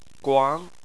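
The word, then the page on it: guang: tone 2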